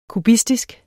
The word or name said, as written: kubistisk